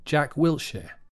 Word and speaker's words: Jack Wilshere: The name sounds like will, followed by a sh sound, like when we say shell or shut. So we have Wilshere, Jack Wilshere.